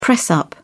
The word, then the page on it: press-up: British English